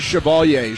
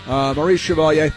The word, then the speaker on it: Chevalier: Her name is Chevalier. She doesn't know how to pronounce it, but I do. And so does Maurice Chevalier.